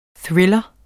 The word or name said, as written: THRILLEREN